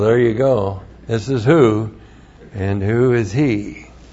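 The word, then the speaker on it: hu: And then we have the personal pronoun who. H-U, long U, Aleph, who.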